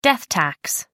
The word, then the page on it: death tax: American English